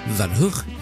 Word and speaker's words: Van Gogh: Van Gogh, pronounced Van Hoogh,